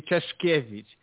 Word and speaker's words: Czeszkiewicz: Aleksander, thank you very much for being here today, and how do you pronounce your last name? – Hello, hello everyone, and thank you for that amazing introduction. My last name is actually Czeszkiewicz, and it's sometimes hard for people from abroad to pronounce it, but it's no problem.